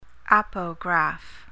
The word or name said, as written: apograph